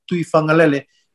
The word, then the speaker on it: Tuifangalele: So the G, when you pronounce the G like Tuifangalele, that G is pronounced as like NG.